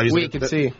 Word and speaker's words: Wicked: Parliamo di Wicked. Dimmi se la pronuncia è giusta. – Wicked, sì.